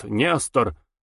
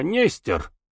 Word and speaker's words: Нестор: Настоящая фамилия писателя была Пимов, но он избрал псевдоним, шутливо объясняя это так: «Ведь у нас не произносят Нестор, а Нестер.